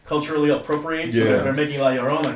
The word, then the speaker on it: La Llorona: No, La Llorona. – Yeah. – La Llorona.